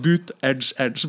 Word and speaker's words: Buttigieg: Ja, han, han, eh, det står på i valgkamphovedkvarteret til han, eh, Pete Buttigieg, så står det ene veggen så står det 'Boot Edge Edge', bare for å vise sånn uttaler man navnet.